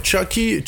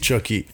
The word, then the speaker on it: Chawki: OK, comment je le prononce? – mais, c'est, mais c'est simple. tu peux, Ouais, ou, tu peux m'appeler. Ouais, Chucky, Chucky c'est simple. Le monde, il m'appelle ici Chucky.